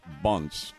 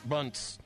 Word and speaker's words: Bunce: And I like the name pronounced Bunts. It would be a great baseball name for a home run king. – Yep, Bunts. B-U-N-C-E.